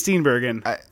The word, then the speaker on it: Steenburgen: Is it Steenburgen or Steenburgen? – I believe it's... – I think it's Steenburgen. – I believe it is a hard G.